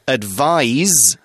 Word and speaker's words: advise: Advice is a noun, it's got a C and it kind of sounds like ice. Advise has got that kind of Z sound, although it's spelt with an S, A-D-V-I-S-E.